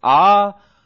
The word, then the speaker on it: ahav: Ahav. But say it like this, Ahav.